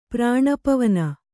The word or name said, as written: ಪ್ರಾಣಪವನ